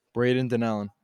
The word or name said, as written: Donnellan